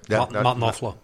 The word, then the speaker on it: Knopfler: They were, yeah, British band, and they were spending some time in New York City, and Mark Knopfler, I think I pronounced that right. – Knopfler.